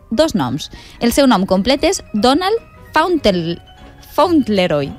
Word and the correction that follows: Fauntleroy: Ui, no ho pronunciem molt bé. – Fauntleroy, sí, sí, Fauntleroy.